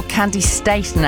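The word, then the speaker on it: Candi Staton: Andy Statton. Or Candy Staten, actually. That's how she pronounces it, and she should know.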